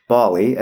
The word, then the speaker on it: Ballie: Yes, Bali. Not to be confused with Wally. – Or the actual Pacific Island Nation. – Yes, right. No, this is spelled B-A-L-L-I-E, Bali.